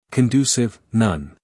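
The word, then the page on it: conducive: uk /kənˈdjuːsɪv/; en-us /kənˈduːsɪv/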